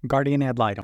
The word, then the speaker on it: guardian ad litem: Guardian ad litem.